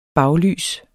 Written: baglys

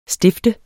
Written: stift

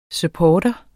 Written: supportere

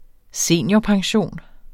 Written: seniorpension

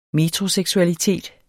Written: metroseksualitet

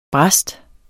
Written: brast

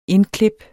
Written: indklip

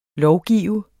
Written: lovgive

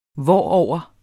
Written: hvorover

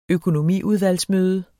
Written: økometer

